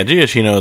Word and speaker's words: Giacchino: But I would say, yeah, Giacchino is what I would guess.